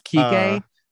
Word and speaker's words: Kike: I know it's pronounced Kike, but it's not great.